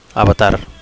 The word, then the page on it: avatar: Catalan